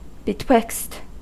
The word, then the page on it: zwischen: [ˈʦvɪ.ʃn̩]; /ˈtsvɪʃən/; [ˈtsʋɪʃən]; [ˈtsʋɪʃn̩]